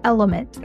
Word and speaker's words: LMNT: This podcast is brought to you by Element, that's spelled L-M-N-T, which is a company that I have the chance to invest in and have loved since day one.